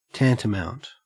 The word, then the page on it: tantamount: Australian English